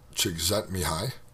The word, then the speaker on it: Csikszentmihalyi: There really are a ton of books, but the 2 that I would recommend are Flow, The Psychology of Optimal Experience by Csikszentmihalyi. That's how you actually say that name, in case you're wondering. How do you pronounce that? It's a Hungarian name,